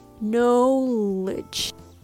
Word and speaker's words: acknowledge: Although it's spelled A C K N O W L E D G E, it's not pronounced acknowledge. It's acknowledge. The second syllable has the vowel sound ah ah knowledge. And keep in mind that the stress lies on that syllable. So the rhythm goes tatada, acknowledge, tatada, acknowledge. Okay?